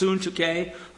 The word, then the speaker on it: Syntyche: Yes, it's pronounced Syntyche. I don't know why everybody pronounces it Syntyche, but Syntyche it is.